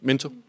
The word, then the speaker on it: Minto: Hvordan udtaler man det egentlig? – Minto. – Det er bare Minto. Jeg hørte nemlig, at sagde Minto. – Det kan man også. Altså, det må man da gerne udtale, hvis man vil. Det er bare ikke korrekt.